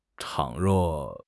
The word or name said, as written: 倘若